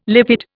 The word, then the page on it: lipid: Indian